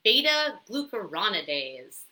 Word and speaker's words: beta glucuronidase: So, this estrobolome microbes basically produce something called, let's see if I pronounce it right, beta glucuronidase.